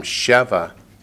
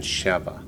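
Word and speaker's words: Sheva (שבע): If it's the masculine column, it's Shiva. The accent's on the last syllable. If it's the feminine form, Sheva, the accent's on the first syllable. So if it's masculine, Shiva, feminine, Sheva.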